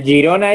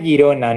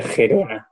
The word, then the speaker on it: Girona: Y el, Girona es Girona, no es el Gerona.